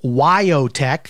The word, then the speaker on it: WyoTech: A local for-profit college by the name of WyoTech, that's spelled W-Y-O-T-E-C-H, WyoTech, is under government investigation.